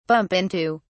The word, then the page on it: bump into: British English